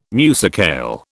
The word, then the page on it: musicale: British English